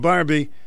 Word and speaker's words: Barbie: I changed it at my other job, too, and began answering the phone, this is Barbie. How can I help you? 90% of callers now respond with my name, Barbie. Can you tell me, pronouncing that long E sound forces your mouth into a smile?